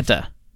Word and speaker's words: Teta: Teta. Like T-E-T-A. Teta.